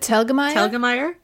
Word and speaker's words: Telgemeier: Shauna Evans says, do you guys read Rania Telgemeier? I think that's how you pronounce it. – Telgemeier? – Yeah.